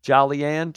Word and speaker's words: Jolyon: Jolyon. It's more like Jaw. Jaw. Jolyon. – Hey, Jaw. How you doing? – Hey, Jaw. What's up, Jaw? Jolyon?